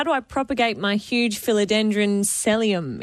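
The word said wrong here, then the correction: selloum: Yeah, yeah, saloom.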